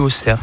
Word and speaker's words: Auxerre: Ici en France, Auxerre, qui s'écrit Auxerre, mais on le dit Auxerre.